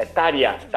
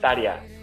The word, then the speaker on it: Tarja: A pronúncia finlandesa do Tarja é como se fosse um I. É Tarja. Tarja.